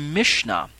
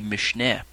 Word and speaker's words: Mishnah: The next term on my list is the Mishnah, spelled M-I-S-H-N-A-H, but it's also spelled without the H on the end at times, Mishnah, or sometimes it's spelled with an E, Mishneh.